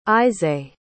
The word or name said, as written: issay